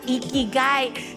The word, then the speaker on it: Ikigai: A su concepto espiritual Ikigai. Sí, así como lo oyes, mi amor. Se escribe I-K-I-G-A-I.